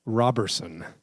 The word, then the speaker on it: Roberson: I'll try to remember he's a Roberson.